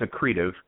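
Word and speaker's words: accretive: Before we do that, Tell us a little bit about you, your background, and is it a creative or a creative? I, am not sure if I'm pronouncing it right. It's a creative.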